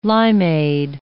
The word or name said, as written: limeade